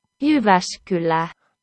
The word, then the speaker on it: Jyväskylä: Valveunia from Mora, M-O-R-A. Good stuff there. We are going to go back to the place that I mispronounced a moment ago. – Jyväskylä. – There you go.